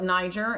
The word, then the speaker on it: Nyjer: So now in the industry, we call it Niger, and we spell it N-Y-J-E-R.